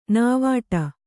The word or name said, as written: ನಾವಾಟ